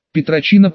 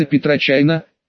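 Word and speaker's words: PetroChina: Петрочина по-русски произносится Петрочайна, китайская нефтегазовая компания.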